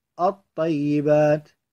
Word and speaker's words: الطيبات: أمثلته مع الطاء الطيبات، لاحظ الطيبات لا تلفظ اللام،